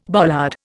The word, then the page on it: bollard: Indian